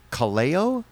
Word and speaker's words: Kaleo: He did a music video of a song by Kaleo? – Yes.